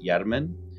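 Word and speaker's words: Jermen: If you look at the name, it looks like German, but it's pronounced Yermen.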